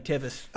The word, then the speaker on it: Walter Tevis: I'm really glad I got that pronunciation right. Yeah. I was really, really on the fence there. – I kind of cued you in with by saying Tevez.